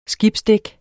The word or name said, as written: skibsdæk